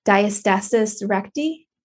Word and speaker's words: diastasis recti: And I know we also wanted to talk a little bit about, and I want to make sure I'm pronouncing this the right way, diastasis recti. Did I totally butcher that? – No.